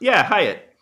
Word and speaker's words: Hyett: And The Seasoning House is a 2013 British horror film directed by Paul Hyatt. I believe that's how it's pronounced. H-Y-E-T-T. White? Hyatt? Anyways. – Hyatt.